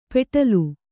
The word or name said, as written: ಪೆಟ್ಲು